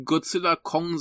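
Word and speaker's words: Godzilla x Kong: Der mich nicht so gut unterhalten hat, ist natürlich Godzilla Kong The New Empire. – Das X ist stumm, ne? Wie in Hunter x Hunter. – Richtig. – Da ist das X auch stumm, deswegen habe ich gerade ausgesprochen.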